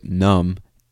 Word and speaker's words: numb: You know, in this case, you don't understand the word numb, N-U-M-B, and the B is silent, numb.